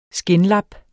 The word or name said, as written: skindlap